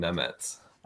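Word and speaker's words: Nemec: And you are the only other person that I've heard pronounce it right. It is Simone Nemets.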